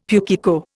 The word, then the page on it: pukeko: Indian